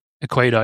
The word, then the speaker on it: Equator: And she was also kind enough to send us some beans from Ecuador Coffees. The Equator, I guess I should pronounce it right.